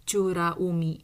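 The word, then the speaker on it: cura umi (美ら海): Eh kan Okinawa suizoku umi wa 15 um, akuarium yang bernama Okinawa Umi ini kenapa tadi saya agak tersendat membacanya ya karena cara baca bahasa Okinawa itu agak berbeda ya. Ini kan B sebenarnya, tapi dia dibacanya cu ya. Ra umi, umi laut sih, tapi jarang kan biasanya curakai misalnya ya, tapi ini dia dibaca cara baca Jepangnya cura umi nih.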